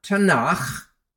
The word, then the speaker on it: Tanakh: So, the writing prophets, their books would come under the second section of the Tanakh, or Tanakh, as it's pronounced.